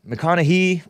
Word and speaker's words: McConaughey: McConaughey? Whatever. Con, as in a confidence. Con man. Con male. It's pronounced naughty, but without the T.